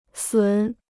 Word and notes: 损: sǔn

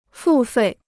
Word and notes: 付费: fù fèi

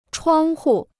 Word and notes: 窗户: chuāng hu

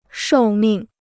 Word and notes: 寿命: shòu mìng

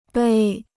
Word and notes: 碑: bēi

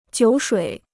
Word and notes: 酒水: jiǔ shuǐ

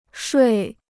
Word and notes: 睡: shuì

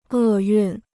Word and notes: 厄运: è yùn

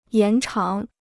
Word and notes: 延长: yán cháng

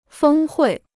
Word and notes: 峰会: fēng huì

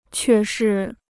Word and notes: 却是: què shì

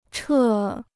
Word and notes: 撤: chè

